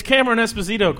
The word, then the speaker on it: Cameron Esposito: It's Cameron Esposito.